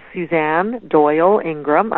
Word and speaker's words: Suzanne Doyle Ingram: My name is Suzanne Doyle Ingram.